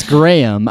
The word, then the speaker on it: Graeme: it's Graeme. I actually looked up the pronunciation. It's like Gray Yum. that's how he spelled it out phonetically.